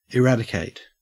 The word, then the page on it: eradicate: Australian English